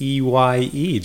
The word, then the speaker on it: Eye: And even the E Y E is pronounced I.